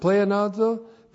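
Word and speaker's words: pleonazo (πλεονάζω): Pleonazo, P-L-E-O-N-A-Z, long O, pleonazo.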